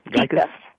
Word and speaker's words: Gykus: Did I pronounce it right, Christina? – Gykus. – Okay, good. Yeah, Gykus, okay. Gykus, mm-hmm. It's a Greek name, yeah.